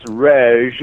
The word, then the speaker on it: Reg: It's Reg. It's R E G, but it almost sounds like an R-E-J. It's a contraction for. – Like active regenerative. Okay. – Active regeneration. So it's okay. Reg Active.